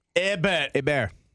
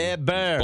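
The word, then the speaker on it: Hébert: Crumble Cookies St. Albert opens this morning on Ebert Road. Ebert. Why do they say it like that? – It's French. – Oh. – A lot of French roads in St. Albert. – Ebert. – Beaumont and St. Albert. – H-E-B-E-R. It's like, yeah.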